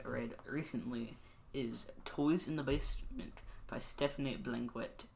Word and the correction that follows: Stéphane: I'm sorry if I said that wrong. – I think it's Stéphane Blanquet. – Okay, I said that wrong. Yeah, it's French.